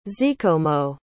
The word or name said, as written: zikomo